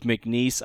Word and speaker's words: McNeese: Gonzaga versus, I've heard this pronounced McNeese State. I've heard it McNeese. We're going to go with McNeese. I think that's the correct way to say it.